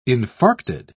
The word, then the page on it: infarcted: British English